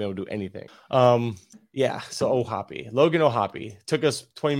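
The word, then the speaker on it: O'Hoppe: Reference has the pronunciation as Ohapi. – Ohapi.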